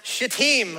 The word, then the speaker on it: Shittim: This word is pronounced Shittim, okay? So y'all just make sure you put the emphasis on the second syllable, okay?